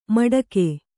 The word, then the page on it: ಮಟಕು: maṭaku